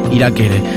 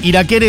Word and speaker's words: Irakere: Iraquere. Escuchen un poquito, porque lo estoy pisando todo, pero quiero que vayan entrando en clima. Ok, bueno, se armó, ¿eh? Un lindo quilombo. Iraquere es un escándalo. Iraquere se escribe como i r a k e r -E.